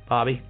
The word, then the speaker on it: Barbie: Did I say that right? Put one on the Barbie? – Bobby.